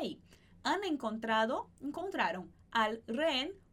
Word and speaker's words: rehén: Então, esse rehén, o H, a gente não vai pronunciar, mas os dois S a gente vai pronunciar.